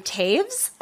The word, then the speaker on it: Toews: Apparently, her name is pronounced Miriam Taves, I think, but it's spelled T-O-E-W-S, so I really apologize for saying their name wrong.